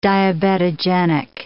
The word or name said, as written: diabetogenic